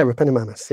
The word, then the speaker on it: Repenomamus: Repenomamus? – Yeah, Repenomamus, yeah.